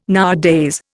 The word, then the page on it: nowadays: Indian